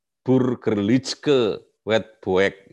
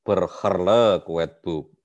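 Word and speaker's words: Burgerlijk Wetboek: Eh, Belanda bahasa Belanda itu kan kalau G itu dibaca G ya. ya. Tulisannya Burgerlitzke wet buek gitu. Kalau tulisannya begitu, tapi bacanya berherle wet book.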